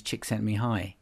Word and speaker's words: Csikszentmihalyi: I think he's Hungarian, a Hungarian psychologist with a name that seems unpronounceable, but it's actually, his name is Csikszentmihalyi.